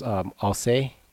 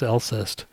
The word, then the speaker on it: Alcest: Oh, we might go see Mastodon, because Alcé is how it's spelled, or how it's pronounced. – Oh, really? – Is it? – I always pronounce Alcest. – Yeah. – But that's just how it's like phonetically, I guess. – I kind of think of C-E-S-T in French as say, but I don't know. Like c'est la vie, Alcé.